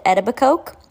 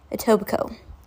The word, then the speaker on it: Etobicoke: If you've watched Don't Fuck With Cats, you know they pronounce it Etobicoke. That is wrong. Etobicoke.